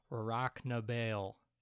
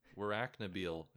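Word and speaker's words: Warracknabeal: Yeah, so he was born in Australia in a town that I will now attempt to pronounce, Rocknabale. I think I might have gotten it that time. – That was pretty goddamn good. Rocknabale is what it looks like to me, and is probably how I will say it.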